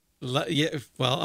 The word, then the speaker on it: la Seine: La Seine. – I'm going to say it like a stupid American, okay? Because that's what I am. – Is it the Seine or the insane? – La Seine.